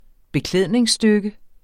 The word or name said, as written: beklædningsstykke